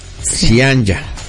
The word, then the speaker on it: Zyanya: Se pronuncia Cianya. – Ah, es correcto. – Sí, y se escribe con Y. – Sí. – O sea, Z-Y-A-N-Y-A. – Cianya.